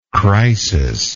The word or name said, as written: crisic